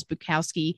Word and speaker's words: Bukowski: Charles Bukowski uh, I did say that right.